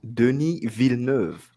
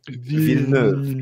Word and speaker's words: Denis Villeneuve: And yeah, since I'm on the panel, well, it's pronounced Denis Villeneuve. There you go. – I'm glad you said that, because I've been pronouncing it differently every single time I've said it. So it's Denis Villeneuve. Is that right? – Villeneuve, yeah.